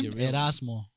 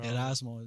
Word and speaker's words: Erasmo: Erasmo. Yeah, Erasmo. A lot of people say Eras.